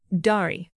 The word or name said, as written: dari